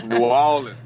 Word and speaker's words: New Orleans: your favorite thing to eat in New Orleans? I said it right. We're good. – Yes. New Orleans.